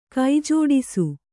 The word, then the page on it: ಕೈಜೋಡಿಸು: kai jōḍisu